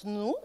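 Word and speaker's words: nosy: nous se dit, comme je le prononce nous, et ça donne le mot nous, et ça veut dire île.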